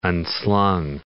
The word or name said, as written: unslung